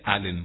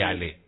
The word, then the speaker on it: Allen: Pero claro, se pronuncia Allen con doble L. Mira vos, no es llave Ale. No, me estaba comiendo la N, entonces.